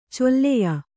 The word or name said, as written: ಚೊಲ್ಲೆಯ